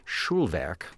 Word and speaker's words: Schulwerk: Because, of course, if you were going to do it in German, it would be Schulwerk, which just means schoolwork.